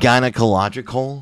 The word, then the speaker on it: gynecological: And sometimes they would have, the doctor would question when they do these gynecology, How would you say that? – Gynecological. – That's right.